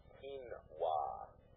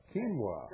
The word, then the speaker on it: quinoa: And I did want to call in and tell Doyle that the strangely pronounced grain is properly pronounced quinoa. – Quinoa.